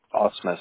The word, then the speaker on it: Ausmus: Ausmus. It's spelled A-U-S-M-U-S. – A-U-S-M-U-S.